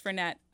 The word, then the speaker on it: Fernet: Well, Fernet, it's actually pronounced Fernet, despite that it's a French word. It's actually Italian in tradition.